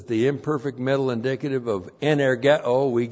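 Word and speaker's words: energeō: Energeo. E-N-E-R-G-E long O. Energeo.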